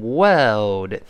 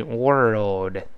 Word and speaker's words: world: 好，那美式发音呢，前面 o r 只不过加了一个卷声音，读成 world。 world 这么一个声音啊，我们再读一下这个词 world，world，哎，这个词不好读 world，wor 先勾舌，再发那个 o 的音 world 这么一个声音啊，怎么样呢？Is boiling。